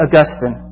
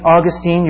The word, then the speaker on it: Augustine: And it's recorded in a book called Confessions by St. Augustine. And if you think it's St. Augustine, you're wrong. It's St. Augustine. And that's the way we're going to pronounce it around here.